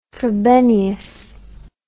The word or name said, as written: Frobenius